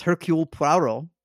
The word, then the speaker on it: Hercule Poirot: You both had a questionable pronunciation, but you were close enough. The correct answer is, especially since you got the first name correct. Correct answer is Hercule Poirot.